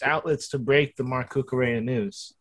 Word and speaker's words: Cucurella: Cucurella. – Cucurella.